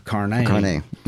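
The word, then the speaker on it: Carné: And of course, we need to talk about Marcel Carnet. – Carnet. Yeah. – Who has been forgotten? You know, I think that's fair to say. – Carnet was up there.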